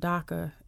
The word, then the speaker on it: DACA: I'm curious what you think or how you feel about the recent decision from President Trump as far as the DACA is mm-hmm.